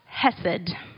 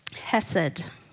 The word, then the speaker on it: Hesed: But the first word is Hesed. You really need some like phlegm in your throat to get that sound right, but Hesed.